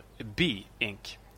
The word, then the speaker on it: Be Inc: Ehm, jag sa att företaget hette BE Inc. Men eh, vår eh, eh, trogna lyssnare eh, Ken Burns Effect uppmärksammade mig på det faktumet att företaget faktiskt heter BE Inc. Alltså som i vara. Bi som i vara. Så rätt ska vara rätt och så är uttalet då.